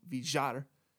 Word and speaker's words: Villar: Or technically, as he wants to be called, Villar, which is literally how he wants it to be pronounced.